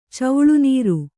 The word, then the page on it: ಚೌಳುನೀರು: Kannada